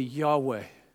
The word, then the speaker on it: Yahweh: You know, I have to do some more research into this, but I saw somebody, we translate it Jehovah. And more recently, scholars tell us we don't know how they pronounced it because they considered it too holy to pronounce. But they say probably Yahweh is closer to it.